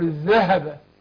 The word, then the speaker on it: الذهب: وبرضه الذهب انا معلش انا ايه تستفيد والكل يستفيد، يعني ما اقول والذين يكنزون الذهب، الذهب فين الهاء؟ اقول الذهب، الذهب مش الذهب، شفت الهاء انا باكد عليها عشان لعلنا لو نخرج النهارده بنطق الهاء وضبطها وتحقيقها ده مهم جدا عشان حرف خفاء ضعيف.